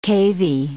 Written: cavy

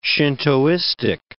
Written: Shintoistic

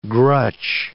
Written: grutch

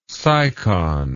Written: sycon

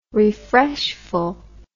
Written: refreshful

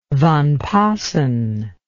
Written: Van Paassen

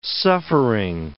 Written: suffering

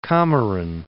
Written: Comorin, Cape

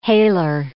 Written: hailer

